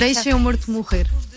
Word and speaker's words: Deixa-me Morto Morrer: No, you Deixa-me Morto Morrer. We eat the vowels, so it's very hard.